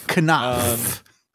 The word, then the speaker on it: Knopf: Pronounce every letter. They put every letter in there. Say every single one. Knopf.